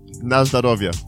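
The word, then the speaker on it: Nazdarovia (на здоровье): But the toast is what? – Nazdarovia. – Nazdarovia. – Nazdanovia. – Yeah, that's your name, man. That's your DJ name. – DJ Vodka? – No, Nazdarovia.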